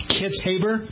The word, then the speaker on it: Kitzhaber: Now, yesterday I got some email from people telling me how to pronounce the former governor's name, John Kitzhaber. I believe that's how I was told to pronounce it.